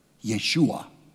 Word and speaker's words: Yeshua: Yeshua. With the emphasis on the second part of the word. Yeshua.